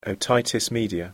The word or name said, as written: otitis media